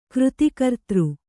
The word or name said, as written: ಕ್ರತುಕರ್ತೃ